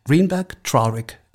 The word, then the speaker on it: Greenberg Traurig: Ähm, du bist Arbeitsrechtlerin bei, ich muss das richtig aussprechen, Greenberg Traurig. Ist das richtig? – Genau so, ja. Man möchte das Deutsch aussprechen, aber dann klingt es falsch.